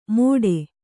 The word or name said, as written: ಮೋಡೆ